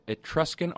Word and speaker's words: Etruscan: She holds a PhD in ancient Roman and Etruscan art.